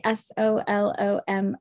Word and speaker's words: Solomon: SOLOMON, Lisa Solomon.